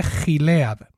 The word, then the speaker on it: Gilead: no, sé si lo estoy pronunciando bien, pero se escribe Gilead, ¿vale? Con G de gato, es decir, Gilead o Gilead.